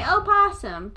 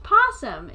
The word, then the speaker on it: opossum: And so they say opossum, but it's just possum. It's just a possum. – Why would you not pronounce the... I've never heard of a silent O.